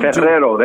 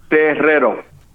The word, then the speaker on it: Terrero: Jim Terrero, there you go. – Say it again. – Terrero. – Terrero? – Terrero. You got to pronounce the R's. You got to roll the R's in there.